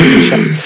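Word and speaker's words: Mysak: Parce que c'était Yann Michak, mais finalement, ça se prononce Michak.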